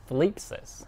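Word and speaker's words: thlipsis (θλῖψις): The Greek meaning is, it's pronounced thlipsis.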